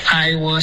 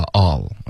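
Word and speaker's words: I'll: I will see you tonight. – No, I will 发 all. OK, 不要把它分开，每一个字都官方的发出来，用官方发音。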